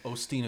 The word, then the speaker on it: Osteen: Austin. – We say it wrong every time. I'm gonna purposefully say it Osteen just to make you angry. No, Osteen is correct.